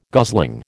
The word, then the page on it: gosling: American English